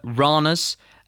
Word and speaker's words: Randers: Leicester beat Denmark's Randers. That is how you pronounce it, spelt Randers.